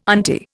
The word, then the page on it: auntie: Indian